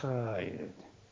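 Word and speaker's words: 하이레떼 (χαίρετε): 하. 하입니다. 하. 크가 아니고요. 하입니다. 예스. 바로 그거예요. 그 발음이요. 하이레떼. 반도떼 카이레떼. 독일 말의 그 R 발음이 그렇게 발음될 때가 많죠.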